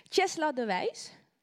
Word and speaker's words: Chesla de Wijs: Chesla de Wijs. Zeg ik het goed? – Ja hoor, perfect.